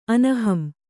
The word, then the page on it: ಅನಹಂ: kannada